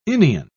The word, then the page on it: inion: British English